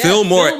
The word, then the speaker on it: Fillmore: Are y'all saying it right? – Yes, Fillmore. Yes.